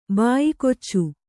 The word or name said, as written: ಬಾಯಿಕೊಚ್ಚು